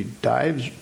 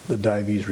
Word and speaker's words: Dives: there's a Latin word, and I don't know if it is pronounced with two syllables or one. D-I-V-E-S is his name. Would it be Dives? Dives? Okay, typically.